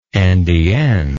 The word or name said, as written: indienne